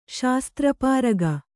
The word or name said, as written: ಶಾಸ್ತ್ರಪಾರಗೆ